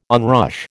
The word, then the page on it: onrush: American English